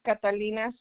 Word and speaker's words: Catalina: My name is Catalina Zaragoza.